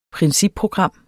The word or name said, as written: principprogram